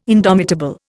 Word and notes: indomitable: Indian

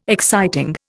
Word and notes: exciting: Indian

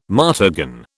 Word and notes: martagon: British English

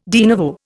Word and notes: de novo: Indian

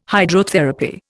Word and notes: Hydrotherapy: Indian

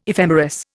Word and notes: ephemeris: Indian

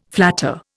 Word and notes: flatter: Indian

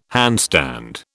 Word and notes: handstand: British English

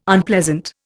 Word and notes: Unpleasant: Indian

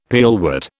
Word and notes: pearlwort: British English

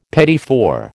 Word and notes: petit four: American English

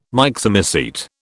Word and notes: Myxomycete: British English

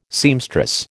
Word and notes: seamstress: American English

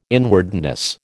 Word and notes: inwardness: American English

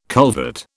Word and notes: culvert: British English

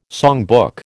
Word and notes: songbook: American English

